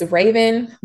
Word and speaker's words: Ravan: It's Raven. My name is not spelled with any E, so it's RAVAN dot J underscore.